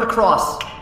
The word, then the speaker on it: across: Oh, and you mispronounced the word across. It's not acrossed. It's across. Across. Across.